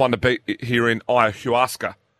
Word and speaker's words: ayahuasca: Um, ayahuasca. So I'm being told ayahuasca. You, rhymes with Oscar.